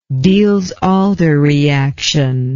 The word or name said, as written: Diels-Alder reaction